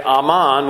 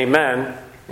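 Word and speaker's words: aman (אָמֵן): And it's the same word from which we get aman, which is amen. We say amen. Hebrew just pronounces it differently, aman.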